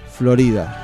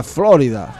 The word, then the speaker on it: Florida: Se escriben igual, pero se pronuncian diferente. Es como la calle, nosotros tenemos acá la calle céntrica, peatonal, se llama en Capital Federal, en Argentina, Florida.